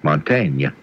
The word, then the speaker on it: Mantegna: Mantegna.